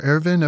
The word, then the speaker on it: Erwin: Erwin or Erwin, I've heard it pronounced both ways.